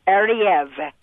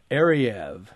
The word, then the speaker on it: Ariew: A R I E W. – Yes, pronounced Ariev. – Ariev.